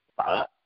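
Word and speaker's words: phở: What is the correct pronunciation? Is it pho or fa? – Fa. It's fa.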